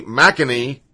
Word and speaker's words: McEnany: Kaylee McClenny. I'm sorry, let's say it right. Kaylee McEnany, I think that's how it is, little blonde girl that's on CNN all the time.